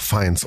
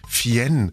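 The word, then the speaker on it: Fiennes: Ach doch, Feins, oder? Ja, ja. – Ein Freund von mir hat mal gesagt, Fien.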